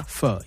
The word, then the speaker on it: pho: I think I'm saying it correctly. Pho. – Pho, yeah. – It's spelled P-H-O. – P-H-O.